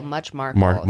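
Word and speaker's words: Much Marcle: He was born on September 29th, 1941 at Bickerton Cottage, Much Marcel, Herefordshire. I probably pronounced that wrong. – I think it's Markle. Much Markle. – Much Markle. Okay.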